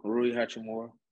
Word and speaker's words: Hachimura: Yeah, Rui Hachimura.